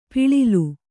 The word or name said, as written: ಪುಳಿಲ್